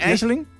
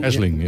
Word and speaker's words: Aisling: Zeg Henk dat goed? Aisling? – Aisling. – A-I-S en dan Ling. – Aisling, ja. – Dat is een Gaelic woord. En dat wordt altijd net even anders uitgesproken dan hoe jullie het schrijven.